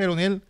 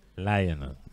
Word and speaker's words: Laionel: Se o sea, así, en, vez de Lionel, en vez de Leonel... Lionel. Lionel. – Ok. – Lo escribieron, lo escribieron como suena en inglés.